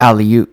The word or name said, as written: Aleut